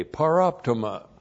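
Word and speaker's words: paraptoma: P-A-R-A-P-T, long O-M-A, paroptuma.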